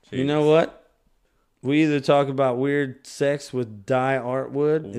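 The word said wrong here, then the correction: Die Antwoord: Is that how you say it? – Di Antwood. – Di Antwood. That's what it was.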